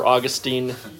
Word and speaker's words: Augustine: Now, a quick note, some people call him Augustine and some people Augustine. I prefer Augustine, just like the city in Florida. I feel like that's an acceptable way to pronounce his name.